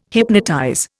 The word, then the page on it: hypnotize: Indian